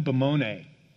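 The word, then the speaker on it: hupomone (ὑπομονή): H-Y-P-O, like hypodermic, and M-O-N-E. And the way it would have been pronounced originally in ancient Greek would have been, this would have been like a U sound, so it would have been hupomone.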